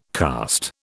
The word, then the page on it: cast: British English